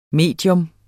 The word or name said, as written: medium